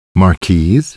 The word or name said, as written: Marquise